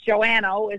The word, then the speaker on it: Joannou: Joannou is how we pronounce the last name. I guess it looks scarier in writing than it actually sounds.